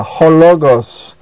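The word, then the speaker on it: hologos (ὁ λόγος): That is a capital lambda, hologos. Again, ho is a proclitic, and it is pronounced with the word logos.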